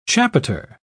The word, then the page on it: chapiter: British English